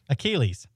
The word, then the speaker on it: Achilles: Achilles.